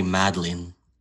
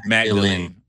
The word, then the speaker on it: Magdalene: Why are you calling her Mary Madeline? – How do you pronounce it? – Magdalene. – Magdalene.